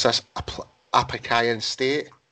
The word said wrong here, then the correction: Appalachian: Appalachian State.